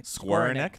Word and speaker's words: Skowronek: Ben Skowronek. Skowronek.